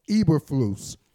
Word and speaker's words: Eberflus: Eberflus. You know? – Okay. – Eberflus, E-B-E-R-F-L-U.